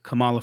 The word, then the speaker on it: Kamala: And it's just like, I feel as though Donald Trump does not respect Kamala Harris. And I think, given all that black people have went through, you have to respect Kamala for putting her hat in the ring, doing all of the right things, and being eligible to be a vice president candidate.